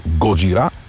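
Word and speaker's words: Gojira: Позволявам си да произнасям с френското ударение на последната сричка – Годжира.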